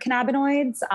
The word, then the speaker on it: cannabinoids: Is THC like the important factor in when you guys are like synthesizing and doing these tests on, I can't even know if I pronounced it, cannabinoids? – Cannabinoids, you're killing it.